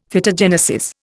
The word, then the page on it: Phytogenesis: Indian